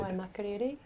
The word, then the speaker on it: Waimakariri: In your leadership position as head of Waimakariki, have I said it right? – Waimakariri. – Oh, so close.